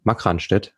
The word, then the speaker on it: Markranstädt: Bei The Stillmans in Makranstedt. Ähm, ah, Markranstädt heißt es ja ausgesprochen.